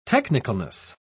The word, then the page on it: technicalness: British English